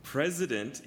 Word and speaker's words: president: 전례가 없는 그리고 president 이렇게 z 발음으로 하는 거는 대통령. president 대통령이라는 말입니다.